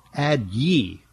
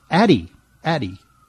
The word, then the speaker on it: Addyi: It's spelled A-D-D-Y-I, which makes it seem like it should be pronounced Ad-Ye. Hear ye, Ad-Ye, Ad-Ye. But no, it's pronounced Addy, Addy, which is kind of the name that you would give to, you know, any listeners in Iowa.